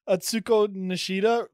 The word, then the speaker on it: Atsuko Nishida: Honestly, you just said Atsuko Nishida like really smooth, and I thought you were going to roll right into it. – Damn. – That was smooth. – Thank you. I'm glad I didn't butcher that one.